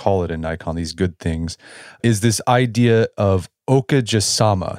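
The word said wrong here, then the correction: okagesama: Did I say that right? You're close. It's it's okage sama.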